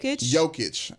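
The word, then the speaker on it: Jokić: Jokic.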